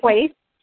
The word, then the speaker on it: Quaithe: to decipher kites, is how I've always said it. But on our last episode, I noticed everybody else said it differently. Kite? How do you guys say her name? Quaithe? – Quaithe. – Quaithe, okay.